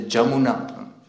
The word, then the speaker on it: Jamuna: And mostly the ypsilon, the y, is pronounced the j. Like Jamuna, they will not say Yamuna, they will say Jamuna.